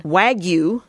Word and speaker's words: Wagyu: Wagyu, yes, that's how you pronounce it.